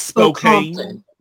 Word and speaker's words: Spokane: Like, you're saying it wrong. It's Spokane.